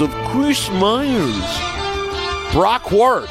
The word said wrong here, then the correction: Huard: Brock Huard, Brock Huard, and Jennifer Hale.